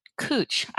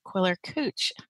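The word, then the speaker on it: Quiller-Couch: On that is any edition that is edited by Arthur Quiller Couch. I'd like to say Couch. And that's how it's spelled, but apparently his name is Quiller Couch.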